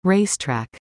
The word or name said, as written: race track